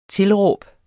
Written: tilråb

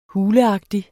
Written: huleagtig

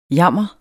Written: jammer

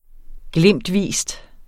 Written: glimtvis

